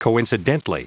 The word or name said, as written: coincidently